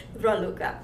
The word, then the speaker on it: Raluca: And I've been called many, many times Racula. And I also have a joke about it. – Okay. – I know I'm coming from Dracula's country. – Uh-huh. – And it's very easy to call me Dracula if you add the D in front of my name. – I never even thought of that. – But my name is Ra. Luca.